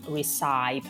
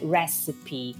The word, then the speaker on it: recipe: Allora, la parola ricetta in inglese non è recipe, ma è recipe, quindi attenzione alla pronuncia. Recipe si scrive R-E-C-I-P-E.